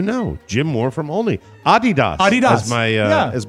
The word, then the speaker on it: Adidas: Regarding Adidas, your mother was actually pronouncing the name correctly. It comes from the name of the founder, Adolf Dassler, whose nickname was Adi.